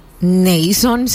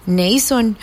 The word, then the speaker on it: Naason: Se escribe N-A-A-S-O-N. Neyson, siento yo. No es así. Neyson.